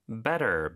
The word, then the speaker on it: better: Better, B-E-T-T-E-R. – 네, better, T가 두 개 들어가지만 물론 하나만 발음하고 모음 사이에 껴서 리을처럼 부드럽게 발음이 되죠.